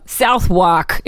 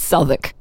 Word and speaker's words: Southwark: Southwark, if you were to say it phonetically, but it's Southwark.